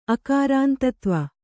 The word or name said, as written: ಅಕಾರಾಂತತ್ವ